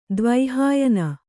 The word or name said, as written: ದ್ವೈಹಾಯನ